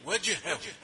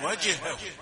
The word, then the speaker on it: وجهه: الجيم شديدة, وجهه أسلم وجهه.